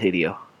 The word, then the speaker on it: Hideo: How do you say his name again? – Who? – Radio Gojira. – Hedio? – Radio? Radio Godzilla. – Hideo. – Hideo. Hideo. Hideo. – Hideo. – Hideo Kojima.